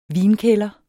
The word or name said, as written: vinkælder